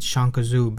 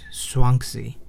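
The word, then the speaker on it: Zhuangzi: There was a Chinese philosopher named, he called it Shangkazu, but I believe the correct pronunciation is Shuangzi. It's Z-H-U. A-N-G-Z-I, I believe.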